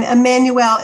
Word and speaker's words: Emanuel: And Emmanuel is spelled for the website E-M-A-N-U-E-L.